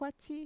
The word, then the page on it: kuatxi: Mixtec